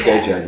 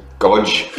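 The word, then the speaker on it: Gojé: G-O-J-E. – G-O-J-E. Okay. Yeah. Gojay. Yeah. – Yeah. – Yeah. I would have looked at the word and pronounced it. Godge.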